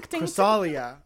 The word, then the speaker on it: Chrysalia: Chrysalia, like nostalgia, like all.